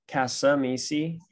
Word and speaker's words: Cascamisi: My name is Jeremy Cascamisi. That second C is silent.